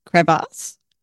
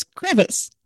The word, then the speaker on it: crevasse: But that's it's like, to, is that how you say it? Crevasse? Crevasse? Crevasse? – Crevasse. You're asking a Kiwi how to pronounce crevasse? English words? – No, I've always read it. I've never said it. And I thought it was crevice. – Well, there's crevice, which is like a rock crevice, and then a crevasse, which is in the glacier, and they're spelt differently.